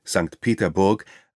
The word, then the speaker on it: Peterburg: Außerdem eroberten sie die Insel Kotlin, ebenfalls nach deutschem Stil in Kronstadt umbenannt, die gemeinsam mit Schlüsselburg die Verteidigung von St. Petersburg, russisch ausgesprochen St. Peterburg, ein weiterer deutsch klingender Name, sicherte der von Peter gegründeten Stadt an der Mündung der Neva in die Ostsee.